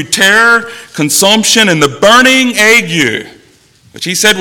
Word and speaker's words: ague: Yeah, this is said ague.